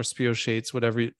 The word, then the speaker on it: spirochetes: So those spirochetes or spirochetes, however you pronounce that. – Spirochetes. – That's the shape of the H.